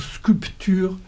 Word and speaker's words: sculpture: En français, on ne prononce plus le P, du moins dans le nord de la France. Dans le sud, on va dire sculpture, en insistant bien sur le P, en disant « nous, on vient des latins ».